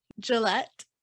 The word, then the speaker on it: Gillette: This woman, Gillette, that's pretty much how you pronounce the like razor company.